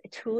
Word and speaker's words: Thule: Thule, is this how we say it? – I think that is the proper way to say it. – I think that's right, right? Okay, so I feel like Thule is starting to really show up in the stroller space, and I'm very happy about that.